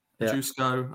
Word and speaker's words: Ožujsko: Ožujsko, I think it is. Ožujsko. – Yeah. Ožujsko.